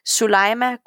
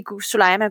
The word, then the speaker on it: Soulaima: Sulaima Gourani is, and maybe if I spell it, it's like S-O-U-L-A-I-M-A.